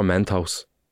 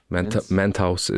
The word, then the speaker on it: Mentos: But it was for Mentos. No, Mentos. Mentos is how you pronounce it. – Mentos? – Mentos. Men's toes.